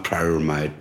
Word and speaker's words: pyramid: It's this old, how do you say, not pyramid, pyramide? – Pyramid. – Pyramid, yeah, pyramid. I always say it wrong, pyramid.